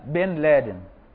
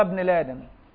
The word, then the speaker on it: ابن لادن: كل ده إملاء، وفي جميع الأحوال بتنطق الألف نطقا، مش هنعمل زي الجماعة يقول لك أسامة بن لادن، هو ابن لادن بس كمان الألف مش مثبتة فما نطقوهاش فقالوا بن لادن واشتهرت، هي أصلا أسامة بن لادن، واخد بالك؟ لكن لأنهم جهل باللغة العربية وجهل بأداب خطها، فلما لقوا ما فيش ألف ما نطقوش الألف، لكن إحنا ننطق الألف حتى ولو لم تكتب، واخد بالك؟